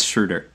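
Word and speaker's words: Schröder: Is that an umlaut? What does that do? Yeah, it's Schroeder. – Okay. – There's an accent on the O.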